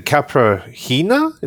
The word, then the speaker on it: caipirinha: Whenever I drink a caipirinha, is that how you pronounce it? – Yeah.